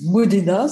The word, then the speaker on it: Modena: alors comme je m'appelle Béatrice Modena, ça s'écrit très simplement comme ça se prononce, M-O-D-E-N-A.